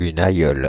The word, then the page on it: nonna: /ˈnɔn.na/